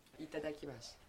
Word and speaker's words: itadakimasu: Itadakimasu. Bon appétit, c'est itadakimasu. Traduit littéralement, ça veut dire merci pour ce repas. Mais on en sert souvent pour dire bon appétit. – Qui veut essayer ? – Itadakimasu.